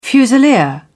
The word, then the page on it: fusilier: British English